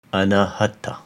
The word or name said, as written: Anahata